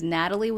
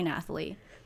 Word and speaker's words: Nathalie: My fucking name is Natalie with an H. Okay. – Yeah. – Nathalie, but the H is silent, so don't call me Nathalie.